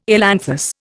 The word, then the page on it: ailanthus: Indian